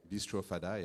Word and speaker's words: Bistro Fada: And let me make sure I say the Bistro Fada. Did I say that right? – Bistro Fada. Yeah.